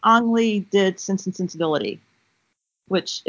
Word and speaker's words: Ang Lee: Ang Lee.